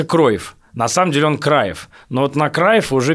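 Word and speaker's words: Cruijff: он же никакой не Круив, он вообще Краев, но в СССР его всегда писали Круив, ну, просто вот транслитом.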